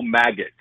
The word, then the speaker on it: Magats: The prosecutor his name is Joe Maggott. I believe it's how you pronounce it, M-A-G-A-T-S.